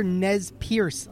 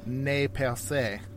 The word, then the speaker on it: Nez Perce: Uh, in in the French, a lot of people would say "ne percé."